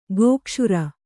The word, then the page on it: ಗೋಕ್ಷುರ: Kannada